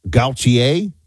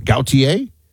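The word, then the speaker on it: Gautier: Did I say that right? It's definitely French. Is that, an origin? Gaultier? Like Jean-Paul, almost like Jean-Paul Gaultier, although people are going to be like, how dare you?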